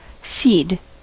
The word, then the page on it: cede: American English